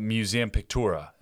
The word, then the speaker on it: Museum Pictura: Well, since we're on the theme of follow-ups, let's talk about Museum Pictura. Am I pronouncing that correctly? – I believe so, yes.